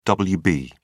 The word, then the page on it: wb: British English